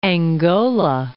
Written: Angola

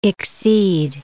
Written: exceed

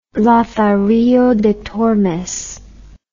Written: lazarillo de tormes

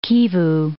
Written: Kivu, Lake

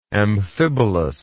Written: amphibolous

